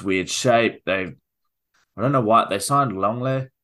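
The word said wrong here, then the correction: Lenglet: Is that how you say his name? – From we'll Barcelona. Go with it. We'll put that in the canon. – French. Should we just do it? Langlet. – Langlet.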